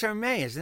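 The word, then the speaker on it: Hermès: It's Hermes, isn't it? There's no S, right? – You know right?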